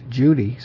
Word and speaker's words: Cudi: Well, of course, in Turkish, which is another language, in that language, the Turkish, we saw that C-U-D-I is pronounced Judy, so the C is pronounced with a J sound, like a soft G, as in the jinn, this J-N-N, and in Fushi, which has the meaning of, we saw before, something like hidden.